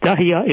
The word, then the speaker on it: Dahiya: Dahiya is a Beirut suburb. I said that right.